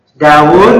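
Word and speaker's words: Dawud (داود): Dawud itu kan Dawud, wawunya ada dua. Ya. Wawunya ada dua aslinya. Tapi dihilangkan wawu duanya. Dawud. Padahal bacanya Dawud.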